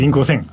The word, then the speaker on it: INC5000: インク5000。 INC5000 と書いてインク 5000と読みます。リスナーの皆さん、ぜひですね、ネットで検索してください。 INC5000 と検索すればすぐ上がります。 2024 年版ですね。今年も公開されました。ちょっとインク5000 が何かということを簡単にご紹介したいと思います。